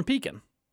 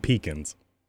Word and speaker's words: Pekin: Thanks for saying it right, finally. – Pekin? I've always said Pekin. – No, you said Pekins. – No. – For the longest time, you both said Pekins.